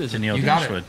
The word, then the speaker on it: Tenille Dashwood: Tenille Dashwood.